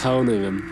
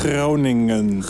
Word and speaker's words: Groningen: You got to roll the R, heavy. – Say that. – Groningen. – Groningen. Night. – No. Groningen.